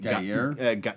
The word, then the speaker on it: Gueye: Guyer? – It's spelled like Guy, G-U-E-Y-E.